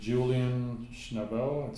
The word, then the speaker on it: Julian Schnabel: There's a documentary on Julian Schnabel. Is that how you say his name? – Yes. Yes.